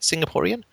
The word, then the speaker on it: Singaporean: is that how you say it, Singaporean? – Yes, that's the pronunciation.